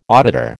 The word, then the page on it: auditor: American English